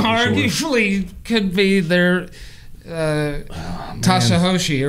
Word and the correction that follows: Satoshi: or whatever how you say it. – That's Satoshi, stacking sats.